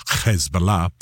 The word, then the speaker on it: Hezbollah: And you hear me pronouncing it a little bit oddly. I'm using the Arab pronunciation, a little bit of Hezbollah.